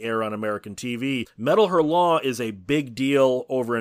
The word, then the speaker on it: Hurlant: Is it Hurlant? You're saying it very ha ha ha. Is it meant to be pronounced like that? – I've always heard it pronounced Hurlant. I've Hurlant. Always heard that the NT is silent.